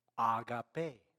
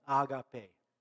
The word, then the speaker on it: agape: Agape. Repeat after me. Agape.